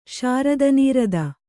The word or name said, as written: ಶಾರದನೀರದ